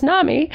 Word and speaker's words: NAMI: Its acronym is NAMI, pronounced NAMI.